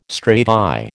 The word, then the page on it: strati: American English